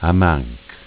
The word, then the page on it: Amanch: dialetto